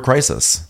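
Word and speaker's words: Crysis: Is that the one that's spelled C-R-Y-S? – Yes. – Okay, that is incorrect. It is. It's actually the word crisis.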